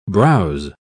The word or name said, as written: browse